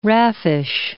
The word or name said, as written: raffish